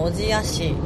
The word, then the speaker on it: 小千谷: 大阪から新潟県の小千谷市、小さい千の谷と書いて小千谷と読むんだと思うんですけど、の片貝町に、で開催されるお祭りの花火を見にちょっと回りくどい言い方なんですけど、これ後でちょっと説明したいと思います。